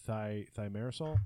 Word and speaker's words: thimerosal: One of the sort of unexpected consequences of taking thimerosal. – Yeah. – Did I say it right that time? – Yep.